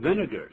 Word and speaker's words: vinegar: 我今天也喝醋啊，我这人最喜欢吃醋了。那我们来看 vinegar 它是怎么拼的呢？ – V-I-N-E-G-A-R. V-I-N-E-G-A-R. Vinegar, 要注意发音哦，因为你看到 V-I-N-E, 可能不小心会念成 vine, 就是藤子蔓藤的意思。